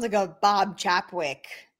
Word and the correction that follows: Chapek: Chapek. Is that you pronounce it? – Bob Chapek, yeah.